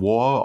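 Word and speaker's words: Waaagh: Es gibt halt den, den War, also Wag geschrieben, wird aber War ausgesprochen. Äh, eigentlich müsste es gebrüllt werden, aber äh, ja, ich nehme Rücksicht auf die Nachbarn.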